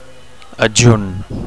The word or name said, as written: adjunt